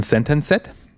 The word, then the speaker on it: Sentenced: Ähm, mein Album ist von der finnischen Metal-Band Sentenced, die hier schon ein paar Mal gefallen ist im Podcast. Oder korrekt ausgesprochen Sentenced.